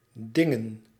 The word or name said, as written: choses